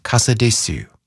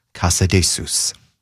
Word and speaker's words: Casadesus: 24, with George Zell and the Cleveland Orchestra, joining pianist Robert Casadesus, or if you prefer the Catalan pronunciation, Casadesus.